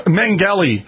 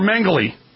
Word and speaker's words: Mengele: Joseph Mengele, or Mengele. Depending on which documentary you watch, that's how they pronounce his last name.